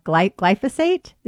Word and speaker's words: glyphosate: And you know, I interviewed recently a medical oncologist, mainstream oncologist, who said, don't eat GMO foods because they have glyphosate.